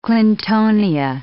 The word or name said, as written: clintonia